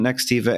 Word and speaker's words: Nextiva: Nextiva and who you are, so we can contextualize your expertise and your insights.